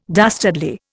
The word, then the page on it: dastardly: Indian